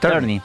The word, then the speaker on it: Tierney: ¿Cómo se pronuncia? – Tierney. – Tierney.